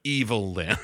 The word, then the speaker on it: Evil-Lyn: Not even just like Evelyn and you pronounce it wrong, but Evil-Lyn.